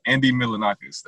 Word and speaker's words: Milonakis: I'm sorry, I said that name wrong. Andy Milonakis, there you go.